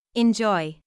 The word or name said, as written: enjoy